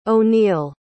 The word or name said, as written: O'Neal